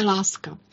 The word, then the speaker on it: Lasca: Jmenoval se Láska. Píše se to Lasca, ale čte se to Láska.